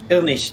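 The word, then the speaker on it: Irnich: It's German, so I apologize that it's awful to spell. Yeah. Ilnish technically, but Ernich is much easier to pronounce.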